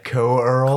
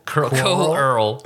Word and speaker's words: Coeurl: I never know how to pronounce that monster's name. C-O-E-U-R-L. – Oh, okay. – Co-Earl. – Quirl? Co-Earl.